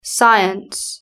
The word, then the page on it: science: British English